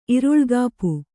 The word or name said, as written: ಇರುಳ್ಗಾಪು